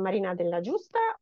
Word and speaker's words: Marina Della Giusta: So I am Marina Della Giusta, and I live in Turin now as of last year, and I am a professor of economics at the University of Turin.